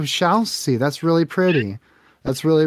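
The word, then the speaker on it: Shalsy: How do you how do you pronounce that? – shall see. – Shall like, see. Like shall see. – Shall like we see.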